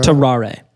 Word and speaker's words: Tarrare: Tarrare.